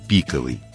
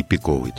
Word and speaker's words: пиковый: Канонически правильно пиковый. Пиковая масть. Но в разговорной речи допустимо ударение пиковый тоже.